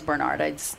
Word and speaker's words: Barnard: When my last name was Barnard, people would ask me to spell it, and I'd say Barnyard without the Y.